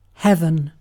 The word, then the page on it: heaven: British English